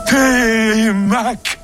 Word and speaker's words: Télémaque: Télémaque, je prononce comme il faut. – Oui, c'est parfait.